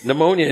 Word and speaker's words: pneumonia: That's pneumonia. – The P is silent.